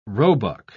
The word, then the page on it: roebuck: British English